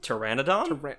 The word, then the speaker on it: Pteranodon: Paternodon? – Pteranodon? – Oh, the P is silent.